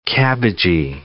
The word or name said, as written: cabbagey